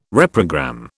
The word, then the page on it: reprogram: British English